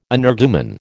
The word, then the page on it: energumen: American English